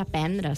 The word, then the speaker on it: aprendre: De fet, no és que es pugui dir, és que el més usual és dir aprendre, sense la R, a la parla nostra del català central.